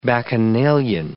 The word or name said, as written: bacchanalian